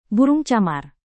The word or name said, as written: burung camar